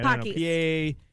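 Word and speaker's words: Pocky: It's called Packy. I think I'm saying that right. – Pockies. – Pockies? Okay.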